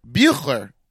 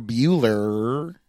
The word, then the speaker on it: Buechler: Or Bueller. – Bueller? – No, but there's a. B-U-E-C-H-L-E-R. Buechler. – Yeah. Right, that's why I said good. You got it right the first time.